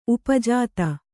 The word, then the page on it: ಉಪಜಾತ: upajāta